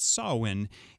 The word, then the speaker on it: Samhain: And if you saw the spelling of that word, you wouldn't believe that's how it's pronounced, but it's Samhain.